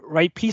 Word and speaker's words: Ripley: Il y en a Tom Ripley,